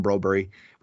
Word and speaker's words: Broberg: Why do we call him Brobery? I get asked this quite a lot. It's just out of courtesy, because that's how you pronounce his name in Sweden.